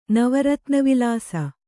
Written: ನವರತ್ನವಿಲಾಸ

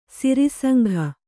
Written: ಸಿರಿಸಂಘ

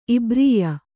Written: ಇಬ್ರೀಯ

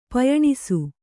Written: ಪಯಣಿಸು

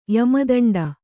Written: ಯಮದಂಡ

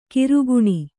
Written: ಕರಗಾಣ

